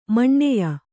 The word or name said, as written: ಮಾನಾಯ